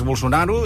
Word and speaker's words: Bolsonaro: Els que en sabem i els que parlem català... – Efectivament. – Ens acostem a la pronúncia brasilera, que és Bolsonaro...